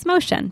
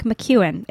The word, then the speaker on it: Mociun: The next person um, is Caitlin Mociun, and I pronounced her last name completely incorrectly for about four years. And it's Motion, but it looks like McEwen. It's M O C I U N.